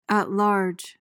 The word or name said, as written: at large